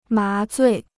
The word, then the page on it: 麻醉: má zuì